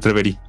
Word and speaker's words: Reverie: Nos acompaña desde República Dominicana, es el director de la clínica eh, Riverí, ¿lo dije bien? ¿O Reverí? Reverí.